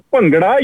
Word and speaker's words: Bhangra: The correct pronunciation is Bhangra. You're very close. The thing is that sound is missing in Western languages. Like first sound like Bhangra.